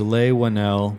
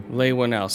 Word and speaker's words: Leigh Whannell: And how would you pronounce that guy's name? The writers? – It's Leigh Whannell. – Leigh Whannell.